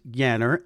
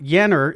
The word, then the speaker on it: Janner: Shelby, your last name is spelled Janner, pronounced Yanner, and you told me a neat story before we started recording. It's not Jenner.